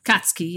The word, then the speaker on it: Akatsuki: And if you remember, the Sioux did Akatsuki. – Mm-hmm. Yes. – Is that pronounced that way? – Katsuki, yes. – Mm-hmm. Yeah, the U is kind of swallowed. So it's almost like it's not there.